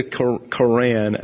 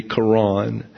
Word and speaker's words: Quran: And in the Quran, actually, is the correct pronunciation, the Quran. And so the secondary pronunciation is the Quran.